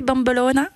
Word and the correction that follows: bombolone: Bombolone. – Bombolone.